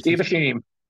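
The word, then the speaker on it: Steve Asheim: Formed by the drummer Steve Asham, sorry, I don't know how to pronounce. Is Steve Ashim.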